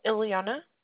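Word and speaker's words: Iliana: It's Ileana. – Can you spell it? – Sure. It's I-L-I-A-N-A.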